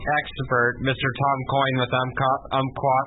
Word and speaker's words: Umpqua: The P is silent. Umpqua.